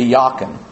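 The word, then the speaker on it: Jachin: If your English pronunciation would be J. Chin, the Hebrew would be Yakin.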